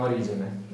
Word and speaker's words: Origene: Origene. Secondo che pronuncia latina, diciamo Origene. Accettiamo.